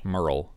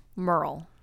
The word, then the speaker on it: Merle: Merle. Merle. I like girl with an M. – Merle. – That's all right. That's not a real name. – M- Merle? – Yeah.